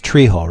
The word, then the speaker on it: Trejo: The his last name is pronounced Trejo, right? Trejo. Yes, I can't get away from the guy because I'm watching Breaking Bad season 2.